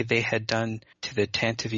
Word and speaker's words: Tantive: Well, I've heard multiple pronunciations of it, but I think the current in canon pronunciation most common is Tantive.